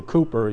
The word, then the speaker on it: Cowper: One of my favorite hymns is, I think he's pronounced it William Cooper, he's spelled Cowper.